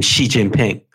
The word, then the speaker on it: Xi Jinping: I call him Xi Jinping. – Okay. – So I'm not sure what the economist is thinking or doing. – Okay. – It all depends on, of course, how you romanize a Chinese character, because what we're talking about is how we pronounce a Chinese character. But Xi Jinping is the right way to go.